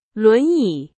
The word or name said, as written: 轮椅